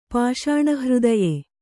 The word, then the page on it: ಪಾಷಾಣಹೃದಯೆ: pāṣāṇa hřdaye